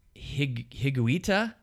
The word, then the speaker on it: Higuita: So, but he had a teammate, Higuita... – Nailed it. Nailed that pronunciation.